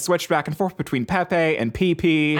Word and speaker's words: Pepe: The original author of Pepe the Frog pronounced it Pepe, so now I am officially changing.